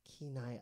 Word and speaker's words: Kenai: Shout out Kenai, Alaska. – So how do you spell that? – K-E-N-A-I. – Kenai, Alaska.